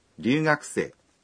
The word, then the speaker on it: 留学生: 留学生是留学生。 – 留学生的留的发音，虽然罗马字写作 R，但读音还是像 L 一样。